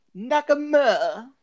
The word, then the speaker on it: Nakamura: Nakamura.